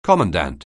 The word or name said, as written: commandant